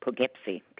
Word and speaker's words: Poughkeepsie: Poughkeepsie, P-O-U. – Poughkeepsie, okay.